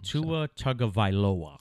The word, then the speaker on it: Tua Tagovailoa: Tua Tagovailoa. – And I don't want to do him dirty by pronouncing it very wrong. – Tua Tagovailoa.